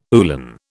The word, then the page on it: uhlan: British English